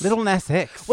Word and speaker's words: Lil Nas X: Lil Nas X.